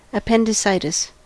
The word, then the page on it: appendicitis: American English